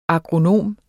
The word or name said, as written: agronom